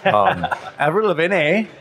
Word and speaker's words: Avril Lavigne: And yeah, I was just telling the story about, I don't know, it was around 2004-ish, serving Avril Lavigne when she was underage. – Avril Lavigne, for anonymity's sake. – Yeah, she's Canadian, so I don't know how it's really pronounced. – Avril Lavigne.